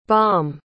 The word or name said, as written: bom